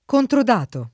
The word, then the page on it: controdatare: lingua italiana